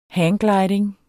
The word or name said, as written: hanggliding